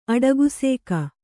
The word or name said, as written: ಅಡಗುಸೇಕ